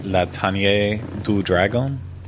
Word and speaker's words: La Tanière du Dragon: Okay, do you want to try pronouncing this one? – I would say, like, La Tanière du Dragon. – Yes, that's what I was thinking. You said it much better, though.